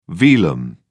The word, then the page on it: velum: British English